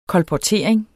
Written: kolportering

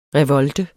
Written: revolte

